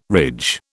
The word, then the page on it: ridge: British English